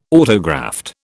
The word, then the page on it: autograft: British English